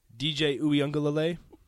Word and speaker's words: Uiagalelei: DJ Uiagalelei. – Is that his name for real? – Yeah. – Yeah. – Did you say it correctly? – Yeah, he did. He nailed it.